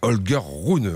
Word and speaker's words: Holger Rune: Voilà, Holger Rune, on prononce ça ainsi.